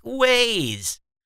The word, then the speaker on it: Waze: Waz is not like Waz. It's pronounced like Waze. Waze is pronounced Waze. Nir, they type it as Waz. Waz. I know Waz is W-O-Z.